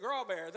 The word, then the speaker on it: Graybert: Now, his name is another one, spelled Graybert, but he pronounces it Grawbear. There ain't no Grawbear nowhere in his name.